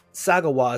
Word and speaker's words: Sagawa: Sagawa is how his last name is pronounced.